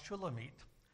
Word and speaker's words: Shulamit: Now, the name Solomon in Hebrew is pronounced Shlomo, and the bride in this book is pronounced Shulamit.